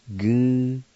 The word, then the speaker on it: Muskogee: And the word for county is spelled S-G-A-D-U-G-I. Skadugi. And now I'll pronounce these words. First one is Muskogee, and it's pronounced Goo-so-e. Goo-so-e.